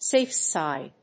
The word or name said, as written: safe side